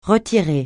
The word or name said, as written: retirer